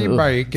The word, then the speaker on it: Baik: Gregory Baik. – It's spelled like it sounds.